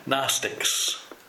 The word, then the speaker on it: Gnostics: Gnostics is, yeah, G-N-O-S-T... G-N-O-S-T-I-C-S. Gnostics.